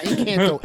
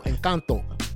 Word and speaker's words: Encanto: It's Encanto. Not Encanto. Encanto. – My bad. I said like an American.